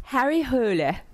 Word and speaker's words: Harry Hole: Harry Hole. I know. Emma, did I say it right? – Yeah.